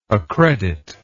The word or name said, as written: accreditment